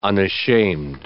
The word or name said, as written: unashamed